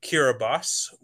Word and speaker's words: Kiribati: It would be hosted in Kiribati, which I just found out how to pronounce. It's spelled Kiribati, which I think more people would be used to pronouncing it as.